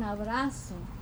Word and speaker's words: abrazo: It's an abrazo. It doesn't rhyme with bug. It rhymes with lazo.